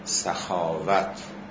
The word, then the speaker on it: سخاوت: البته این را هم عرض کنم، این که ما اینطور تلفظ میکنیم، تلفظ درست تمام این واژه ها، یعنی کرامت و سخاوت و شرافت و نفاست، به فتح اول است. یعنی کرامت، سخاوت، شرافت، نفاست باید به فتح اول تلفظ بشه.